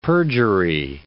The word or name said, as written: perjury